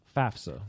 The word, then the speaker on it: FAFSA: FAFSA. – FAFSA. FAFSA. FAFSA. – FAFSA. – That's a hot initialism. Oh, would that be an acronym? – That's an acronym because we pronounce it FAFSA.